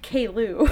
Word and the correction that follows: Caillou: Caillou. I think it's pronounced Caillou.